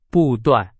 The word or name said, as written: 不断